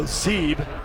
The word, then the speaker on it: Sieb: And Armando Zeeb, spelled S-I-E-B, but pronounced Z-E-E-B.